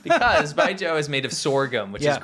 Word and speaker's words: Baijiu: It's B-A-I-J-I-U.